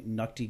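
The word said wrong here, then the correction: Ncuti: Gatwa, but it's Shuti is how it's pronounced. – Yeah, I think the N is silent, it's just Shuti Gatwa.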